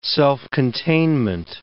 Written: self-containment

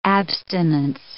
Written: abstinence

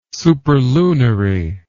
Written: superlunary

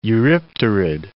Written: eurypterid

